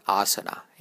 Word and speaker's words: asana: It's spelled as A-S-A-N-A. It's pronounced as asana. Asana. You'll hear many people pronounce it as asana. This is your first tip for correct Sanskrit pronunciation. Get to know which vowel is long and which one is short.